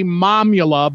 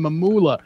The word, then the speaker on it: Mamula: He, and actually, the pronunciation was supposed to be Momula, but they always called him Mamula.